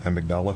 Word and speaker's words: Amygdala: I can't pronounce that one. Amy Gadala? – Amygdala. – Amygdala. – Amygdala. Okay, thank you.